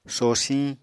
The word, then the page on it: sosiñ: Apatani